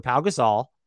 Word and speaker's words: Pau Gasol: Pau Gasol, for the pronunciation there, and Lamar Odom.